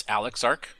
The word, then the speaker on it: Alexarc: It's Alexarc, but Alex is fine.